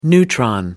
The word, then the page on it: neutron: American English